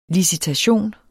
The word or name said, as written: LICITATION